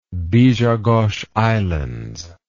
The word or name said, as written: bijagos islands